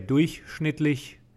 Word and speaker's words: durchschnittlich: I would I would pronounce it durchschnittlich. And you're saying it a little different. Durchschnittlich. Durch. Yeah. Is that just a weird because durch is a word, like by itself, like through?